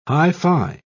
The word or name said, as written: hi-fi